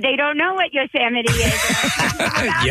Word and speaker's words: Yosemite: And I had to read an article about a park called Yosemite. – Yes. – Yosemite.